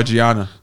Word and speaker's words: Gianna: Yeah, Jenna Michaels was my boo. – Oh, Gianna. – Gianna, thank you.